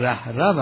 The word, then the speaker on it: رهرو: ای رهرو، رهرو که تلفظ درست این واژه رهرو است، به مفهوم سالک، طالب و آن که قدم به سیر الالله نهاده است، قدم در راه سیر الالحق نهاده است.